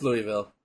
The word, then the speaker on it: Louisville: Or do you guys say Louisville? – It's Louisville. – Okay. – Some idiot might pronounce it Louisville, but it's Louisville.